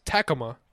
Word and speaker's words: Tacoma: I think it's cooked beans, first off, it's pronounced Tacoma.